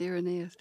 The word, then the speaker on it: Irenaeus: Now, you credit Irenaeus, am I saying his name right? – Yes, Irenaeus.